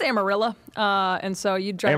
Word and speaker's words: Amarillo: It's pronounced Amarillo. – Oh, my bad. – What did you call it? – Amarillo. I was, tried to do it the Spanish way.